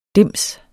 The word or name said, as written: dims